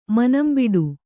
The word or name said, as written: ಮನಂಬಿಡು